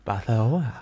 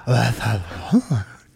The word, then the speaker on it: Barcelona: Barcelona. Barcelona. Det er sådan, de udtaler det. – Nå. Altså, ikke? – Jo, Ja. – Barcelona. Tungen den skal bare hænge ud af kæften.